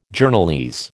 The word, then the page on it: Journalese: American English